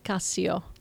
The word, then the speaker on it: Cassio: It's actually Italian and it's pronounced Cassio.